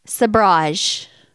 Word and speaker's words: sabrage: Sabrage. – Sabrage? – And I may be pronouncing that wrong. – It's like a mirage, but Sabrage? – S-A-B-R-A-G-E.